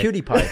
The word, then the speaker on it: PewDiePie: Pootie? – Bro, I don't think that's how you say his name. Why do you say it? PewDiePie. – Oh, PewDiePie. PewDiePie.